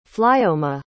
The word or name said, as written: flioma